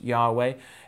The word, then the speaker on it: Yahweh: That's because it's the name of God, YHWH, commonly pronounced Yahweh.